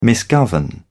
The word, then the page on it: misgovern: British English